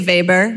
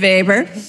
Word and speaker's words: Weber: I don't know why I didn't know this, but somebody messaged me and said, do you know your name, your last name, Weber, which is spelt wrong. I should say Weber. My German friends would tell me. Yeah, that's right, Weber.